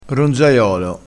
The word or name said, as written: ronzaiolo